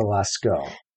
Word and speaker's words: Lascaux: And this, everything he says here reminded me of the, how do you say it? Lascaux cave paintings? – I think it's the Lascaux.